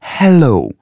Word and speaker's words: hello: Ты забыл, что звук «ха» произносится нежно, с придыханием. Hello.